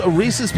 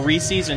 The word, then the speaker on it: Reese's: It's pronounced Reese's. It's not Reese's, and you just sound silly, Apple D.